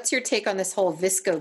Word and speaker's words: VSCO: So where the VSCO girl, nomenclature, the word VSCO comes from an app that stands for it's VCSO, and it's pronounced VSCO, like disco. – Okay. – But with a V.